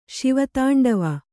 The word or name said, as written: ಶಿವತಾಂಡವ